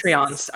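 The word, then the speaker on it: Patreon: Is that how do you pronounce that? I've never actually said that out loud before. – Patron, yeah. Patrons and watching. Oh, artists. Patreons. – Are you talking about the now? Yeah, Patreons.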